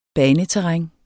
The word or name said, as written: baneterræn